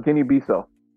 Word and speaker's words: Guinea-Bissau: Group A, you have Ivory Coast, Nigeria, Equatorial Guinea, and Guinea-Bissau. Did I say that right? – Guinea-Bissau. – There you go.